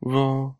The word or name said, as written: vo